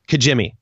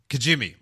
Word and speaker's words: Kijimi: And there's one shot when they're on Kimji? – How do you pronounce it? Kijimi. – Kijimi.